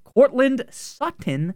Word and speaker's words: Courtland Sutton: Courtland Sutton today, Junior.